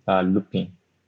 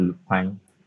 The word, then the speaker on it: Lupin: So let me just say, so currently I'm watching Lupin. If I'm pronouncing it well, Lupin or Lupine, L-U-P-I-N. Yeah, so Lupin.